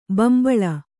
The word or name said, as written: ಬಿಂಬಲಿ